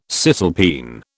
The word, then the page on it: cisalpine: British English